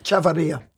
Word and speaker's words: Chavarria: I can never get his name right. Willy Chavarria. – Right. Chavarria. – Chavarria. Thank you.